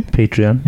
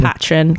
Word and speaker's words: Patreon: And I set up a, I can't pronounce this word, a, a, a Patreon. – Patreon. Yeah, I call it really? Patron because I'm a chav.